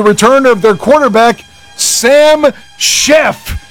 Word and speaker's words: Szefc: But if you were spelling that, trust me, you would not say Sheff. His last name is spelled S is in Sierra, Z is in Zulu, E is in Echo, F is in Foxtrot, C is in Charlie. S-Z-E-F-C. It's clearly Sam Sheff.